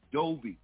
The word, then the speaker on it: Doby: But his last name is pronounced Doby.